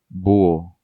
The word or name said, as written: Buho